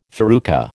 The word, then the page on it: farruca: American English